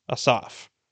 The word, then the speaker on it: Asaph: Psalm 50 is written by a man named Asaph, or you might have been pronouncing it Asaph your whole life, much like it was for me. But looking it up in how the Hebrew name is spoken, it's actually pronounced Asaph.